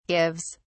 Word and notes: gives: British English

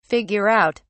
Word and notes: figure out: British English

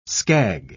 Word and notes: skag: British English

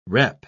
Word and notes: repp: British English

